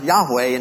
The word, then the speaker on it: Yahweh: In Hebrew, it's pronounced Yahweh, and it's where we get when God said, I am that I am.